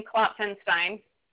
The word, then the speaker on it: Klopfenstein: Klopfenstein.